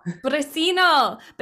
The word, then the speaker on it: Briceno: Briceno.